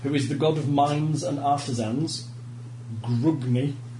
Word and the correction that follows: Grungni: Lovely. Oh, it's Grungni. I can never say it right.